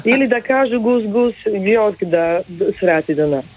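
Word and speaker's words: GusGus: Gus Gus, ja to zapamtim, zato je to nastalo od Kus Kus.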